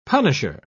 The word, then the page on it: punisher: British English